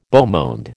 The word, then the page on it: beau monde: American English